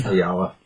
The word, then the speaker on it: Ayala: Well, book-wise, we have the writer on this is Vita Alia, I think is how you say it. It's A-Y-A-L-A.